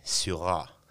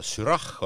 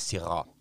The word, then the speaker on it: Syrah: siis seal hääldatakse teda ikkagi Syrah, Syrah. et jah, kirjutatakse S-Ü-Y-R-A-H nagu soomepäraselt võiks olla sürah, onju, eestlased kipuvad ka ikkagi süra ütlema, tegelikult on ta sira.